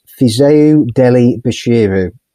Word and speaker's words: Fisayo Dele-Bashiru: Um, it's Fisayo Dele-Bashiru uh, is I believe how that's how you pronounce it. Uh, but yeah, sounds um, sounds right to me.